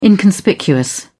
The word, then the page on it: inconspicuous: British English